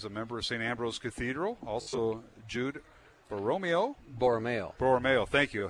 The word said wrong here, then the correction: Borromeo: I had the accent on the wrong syllable once again. Borromeo.